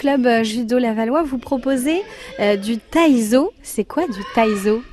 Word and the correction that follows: taiso: Alors du coup ça se prononce du taiso,